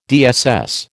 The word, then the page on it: DSS: American English